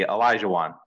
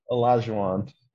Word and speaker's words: Olajuwon: Give me help with pronunciation. – This one's easy. Olajuwon. – Olajuwon.